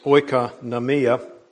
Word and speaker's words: oikonomia: The meaning of the term that we have translated stewardship, the Greek word is pronounced oikonomia, and it's a combination of two very common words, oikos, which is a word for house, and nomos, which is a word for law, so house law, or the law of the house.